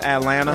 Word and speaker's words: Atlanta: You notice that people from Atlanta do not pronounce the T. It is Atlanta. Atlanta. – Well, yeah. You spell it. A-T-L-A-N-T-H-E-A is where I stay.